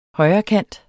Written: højrekant

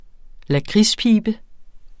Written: lakridspibe

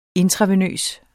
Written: intravenøs

